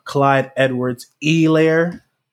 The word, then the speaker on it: Clyde Edwards-Helaire: Clyde Edwards-Helaire, because, you know, we're emphasizing that the H is silent, had 26 carries for 161 yards, had 4 catches on 4 targets for 8 yards.